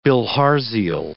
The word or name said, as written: bilharzial